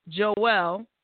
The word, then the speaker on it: Jouelle: If you have a question for Jouelle, did I say that wrong? I feel like I'm saying it wrong. – That was right.